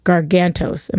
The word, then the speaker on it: Gargantos: is actually going to be named Gargantos. Am I saying that right? – Yeah, you are.